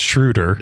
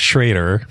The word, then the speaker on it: Schroeder: If you're doing the German, it's Schroeder. – Schroeder. Yeah. With the umlauts, right? – The Milwaukee people say it's Schrader.